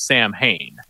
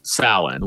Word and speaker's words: Samhain: So the way they said it in Halloween 2 is Samhain. – Yes. – Which is how Glenn Danzig pronounced his band name as well. It's actually pronounced Salin, which is how they pronounce it in this movie.